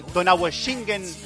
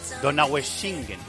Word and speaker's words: Donaueschingen: En la concentración de la selección española en Donaueschingen, creo que lo he dicho bien. Elena Condis, hola Elena, muy buenas. – Lo has dicho perfectamente. – Donaueschingen. No es Schingen, es Schingen. – Schingen, sí.